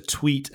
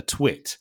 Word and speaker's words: tweet: It's a tweet and not a twit, right? And a tweet and a twit are very different things.